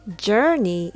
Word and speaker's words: journey: Journey is pronounced with er and not or. So it's journey, not journey.